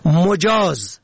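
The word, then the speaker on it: مُجاز: مجاز به ضم اول، اجازه داده.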